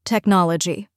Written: technology

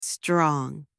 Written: strong

